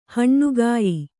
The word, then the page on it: ಹಣ್ಣುಗಾಯಿ: haṇṇugāyi